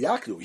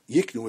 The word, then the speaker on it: yaknu: My time all occurs be yaknu. Here you're telling me you have to say that this word should be read Yaknu, not Yiknu as we pronounce it, but it should be thought of as Yaknu because we want to have it that the seller is the one that gives the document.